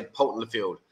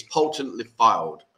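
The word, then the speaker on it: Poulton-le-Fylde: It was from someone else, because I just say Poulton Lefield. And they said, that's not how you pronounce it. So, okay, it's Poulton Lefield.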